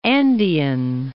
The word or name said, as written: Andean